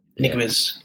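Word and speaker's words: Ñíguez: Niguez.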